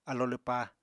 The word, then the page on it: alo lwpá: apatani